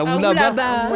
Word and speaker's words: Oualaba: Attends, non, mais comment mais tu l'as prononcé Oualaba. – C'est Oualaba. – Ouais, je l'aurais dit comme ça aussi.